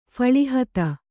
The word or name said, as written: ಫಳಿಹತ